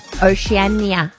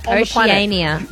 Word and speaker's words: Oceania: How do you say that, by the way? – Oceania. – The most confusing word. – I need to see it. – Oceania.